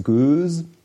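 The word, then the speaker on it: Gueuze: Kann man auch dann 2 Jahre lagern, dann wird es Göse. – Kann man so spricht man das aus.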